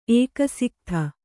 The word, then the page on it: ಏಕಸಿಕ್ಥ: Kannada